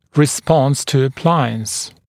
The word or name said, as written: response to appliance